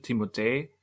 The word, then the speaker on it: Timothée: Also ich glaube, er, ich glaube, die korrekte Aussprache ist mehr diese französische Timothée, mhm. weil er ja mit, mit TH, e, äh, e mit Akzent und noch ein E geschrieben wird.